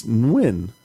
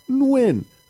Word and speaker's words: Nguyen: His last name's Nguyen, and I would say, how am I saying this wrong? And he'd say, it's Nguyen. And I'd say, Nguyen. And he'd go, no, Nguyen. Oh, Nguyen, no. And I'm like, fuck, it sounds the same.